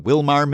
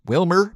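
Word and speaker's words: Willmar: First, in the past blast from episode 1903, there was a story that featured a town I kept calling Wilmar, Minnesota. Well, I've been reliably informed that it is pronounced Wilmer, Minnesota. Apologies to our Wilmer listeners. My bad, obviously. Who else's bad would it be? Maybe Wilmer bears part of the blame for spelling it with an A instead of an E.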